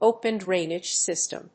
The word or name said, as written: open drainage system